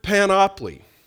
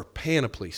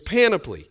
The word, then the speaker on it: panoply: Well, the word here for armor of God, the whole armor of God, is the word panoply. Or panoply, sorry. My mind wants to go to monopoly, and I say it wrong every time. It's panoply.